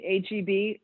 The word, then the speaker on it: H-E-B: H-E-B or G-B? H-E-B. – Yeah, H-E-B. – Is that what people call it? – Yeah, that's what it is called. – Okay. – It's H-E-B. So H-E-B is, like, humongous.